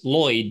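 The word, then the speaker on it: Loyd: So my last name is unusual because it's Lloyd, but it's spelled with one L. So it's L-O-Y-D.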